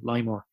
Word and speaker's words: LIMOR: And that's where the name LIMOR, your pronunciation, LIMOR, LIMOR, LIMOR came from. So LIMOR, LIMOR, or less is more.